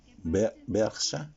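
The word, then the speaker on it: Brejcha: Yo les recomiendo la de Boris eh, Bercha. Ese es el apellido, así se pronuncia.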